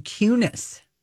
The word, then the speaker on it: Kunis: I didn't know you pronounced her name Kunis. – Yeah, I mean, Carson would know, right? – Mila Kunis. We called it Kunis. But it's you. It's K-U-N-I-S. Yeah.